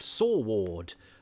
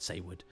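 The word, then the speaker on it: Saward: Eric Saywood, I used to think, it's a funny name, Saywood, I certainly used to think it was pronounced Sawward until I saw a documentary about a true crime, and one of the people involved was called Saywood. And then I interviewed Colin Baker, and he pronounced it Saywood.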